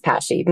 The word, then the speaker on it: PASSHE: PASSHE? – Yes, you could, yes, PASSHE.